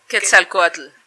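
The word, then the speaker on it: Quetzalcóatl: Quetzal, Quetzalcoatl.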